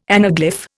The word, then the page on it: anaglyph: Indian